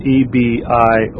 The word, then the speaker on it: Ebionites: The Arians, Ebionites, that's Ebionites,